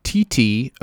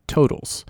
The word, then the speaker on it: TTotals: T-T-O-T-A-L-S. Totals. For many years, I pronounced it T-Totals or T-Totals. Lots of different ways to approach that, but then I just asked them directly, how in the world do you pronounce this? And they informed me that it's just Totals.